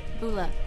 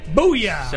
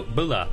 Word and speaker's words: Bula: Booyah! So, like no. Stuart Scott? – No, Bula. That's Mel. – B-U-L-A.